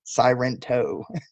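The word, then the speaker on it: Sairento: Sirento. – you got to say it like you're Japanese.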